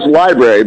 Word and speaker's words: LBRY: Jeremy, how do you pronounce the organization, the app, the service? Is it, it's LBRY. How are you guys saying it? – We're saying it as Library, but I love that you're spelling it out for your listeners.